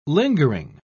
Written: lingering

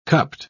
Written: cupped

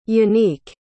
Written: yanick

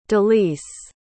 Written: delice

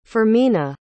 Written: fermina

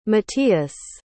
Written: matias

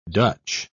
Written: dutch

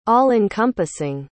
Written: all-encompassing

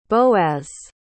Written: boaz

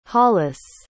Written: hollace

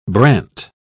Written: brant

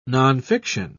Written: nonfiction

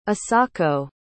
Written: isako

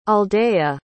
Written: aldea

